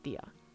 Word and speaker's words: tía: But tío is spelled T-I-O, and tía is spelled T-I-A, and the I has an accent on it in both of those words. Tío and tía.